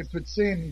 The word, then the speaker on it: poutine: The French would say poutine, but mostly everybody else calls it.